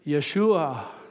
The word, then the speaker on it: Yeshua: And the Jewish people would pronounce it Yeshua.